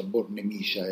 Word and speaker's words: Bornemisza: il traduttore su internet suggerisce che in ungherese la pronuncia Bornemiscia è, è corretta. Comunque, il museo Tirsen Bornemiscia raccoglie eh,